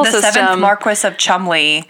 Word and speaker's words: Cholmondeley: He's the Marquess of Chumley. And do you know that name? – It was in the news, wasn't it? – It's spelled like Cholmondeley. – Yeah. – It's one of those very goofy British last names.